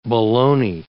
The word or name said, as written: baloney